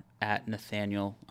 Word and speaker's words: Nathanael: And both of those are @Nathanael_. And my name is spelled A-E-L, not I-E-L.